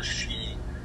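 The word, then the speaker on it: sídhe: It doesn't say she here, but it says further down, it says working with the inner realm of the she. It is pronounced just like you say she to a woman. I mean, but it is Gaelic, so you write it differently than you pronounce it.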